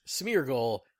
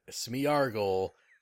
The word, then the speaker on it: Smeargle: The Pokemon that resembles a dog with a paintbrush for a tail, his name is pronounced Smeargle and not Smeargle, which I said in passing last episode, and my girlfriend roasted me about it.